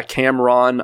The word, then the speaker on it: Cameron: Um, but yeah, Cameron Ayala, that's the best way to find me on the Instas.